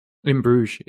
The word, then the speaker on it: In Bruges: And then, and I'm going to say this wrong, so please correct me, but In Bruges? – In Bruges is correct, yeah.